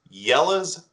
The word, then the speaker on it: Jelle's: I'm going to endorse a YouTube channel called Yella's Marble Runs. That's spelled J-E-L-L-E-S, Marble Runs.